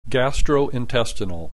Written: gastrointestinal

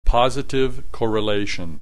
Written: positive correlation

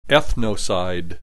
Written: ethnocide

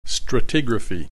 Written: stratigraphy

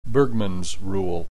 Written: Bergmann's rule

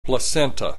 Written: placenta

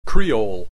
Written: creole

